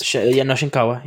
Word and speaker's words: Shinkawa: Yuji Shinkawa? Shinkawa? – I think I feel like I'm butchering this. – Shinkawa. You're right.